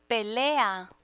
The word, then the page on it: pelea: Spanish